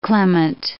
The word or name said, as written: clement